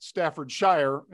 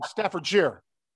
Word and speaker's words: Staffordshire: And I found out already, folks, that I mispronounced Staffordshire. I pronounced it Staffordshire, and he said Staffordshire. Staffordshire, John.